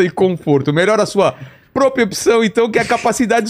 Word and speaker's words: propriocepção: Melhora a sua propriepção. Falei certo? – Propriocepção.